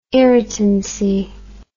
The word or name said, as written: irritancy